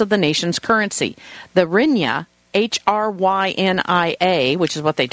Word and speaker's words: Hryvnia: Hryvnia.